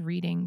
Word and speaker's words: Reading: The word is Reading, but it's, I think, pronounced Reading. West Reading, Pennsylvania.